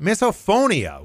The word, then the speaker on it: misophonia: We're getting through October here and talking about misophonia, which I'd never heard of until the start of the month.